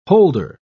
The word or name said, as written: holder